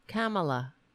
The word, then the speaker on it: Kamala: If that's, how do you pronounce her name? Kamala, right? Kamala. That's how you pronounce it.